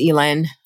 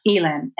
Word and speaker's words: Elin: So yeah, I'm listening to The Exiles on audio and I've been reading 28 Summers by Ellen Hildebrand. This is my first Ellen Hildebrand or Elin. I'm not sure how to pronounce her name. – And I think it's Elin. – Elin.